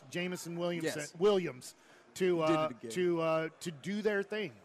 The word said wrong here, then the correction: Jameson Williams: Jameson Williams.